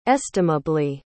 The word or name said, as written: estimably